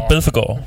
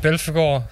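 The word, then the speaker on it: Belphegor: Bel, Belfegård. Belfegård, er sådan udtale det.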